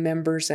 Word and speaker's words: members: Members, members with a Z sound on the end. I'm not sure why. Oh, because er is kind of like a vowel sound. Even though you see an R there, ER is more like a vowel sound, therefore the S is a Z.